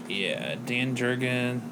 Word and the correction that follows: Jurgens: writes it. – Jurga? – Huh? – Jurga? – Is that how you say it? How do you say it? Jurgens, sorry. – Oh. – Jurgens.